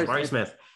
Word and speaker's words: Jabari Smith: Jabari Smith. Am I getting the name right? – Yeah. – I was saying it the whole year. I'm blanking right now. Yeah, Jabari Smith is good.